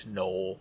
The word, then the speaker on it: Knoll: I was imagining things you could call it like Southgate Summit, or Grealish Green, or Kane Knoll, which is a silent K, not as good.